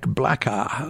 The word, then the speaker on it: Blacka: They are Chris Ellingworth on piano, Nick Blacka, let's like Black with an A at the end, B on bass, and John Scott, or Johan Scott, not sure how he pronounces it, on drums.